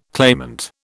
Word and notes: claimant: British English

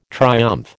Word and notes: triumph: American English

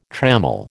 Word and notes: trammel: American English

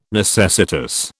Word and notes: necessitous: British English

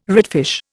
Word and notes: redfish: Indian

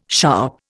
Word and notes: sharp: Indian

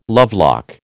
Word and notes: lovelock: American English

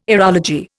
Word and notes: aerology: Indian

